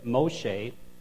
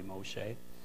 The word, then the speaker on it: Moshe: But in Exodus 3, Moshe, and we call him Moses, but Moshe, because the Hebrews would pronounce it probably Moshe, and the burning bush and the name of God.